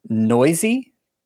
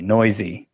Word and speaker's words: Neuse: No, but I did want to draw attention to another player in Oakland's lineup, and that is, oh man, I'm glad I looked up the pronunciation before I said it, Sheldon Noisy. – Yep, that is how you say his name. I remember hearing it and I was like, what? – N-E-U-S-E, Noisy.